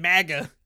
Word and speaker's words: MAGA: Also, why are you pronouncing it like it's manga? – But whatever, man, MAGA.